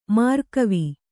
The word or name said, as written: ಮರಗಿವಿ